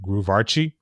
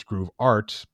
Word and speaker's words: Groove Art: If it's in Portuguese, you pronounce it Groove Archi. And in English, it's Groove Art, but it's an art with an E at the end, okay.